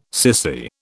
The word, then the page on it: cissy: British English